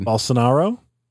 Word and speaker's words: Bolsonaro: Bolsonaro? – That was pretty good, yeah.